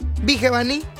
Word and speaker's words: Vigevani: No sé si ustedes ya vieron eso de que esta Karina Torres, la licenciada Karina Torres, estaba en el jacuzzi con Fede Villevani. Sí se pronuncia así, ¿verdad? Vigevani. – Ay, wow. – Una disculpa, Fede Vigevani.